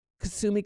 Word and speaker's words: Kusumi: Kusumi, and that's spelled K-U-S-U-M-I, Kusumi.